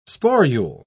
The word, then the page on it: sporule: British English